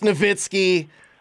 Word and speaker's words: Nowitzki: It's Nowitzki.